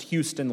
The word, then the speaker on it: Huston: It's pronounced Houston, like the Houston. City, Your Honor.